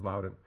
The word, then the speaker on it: Loudon: The Loudon sisters, and here I'd like to note that previously I had mispronounced their name. It is Loudon.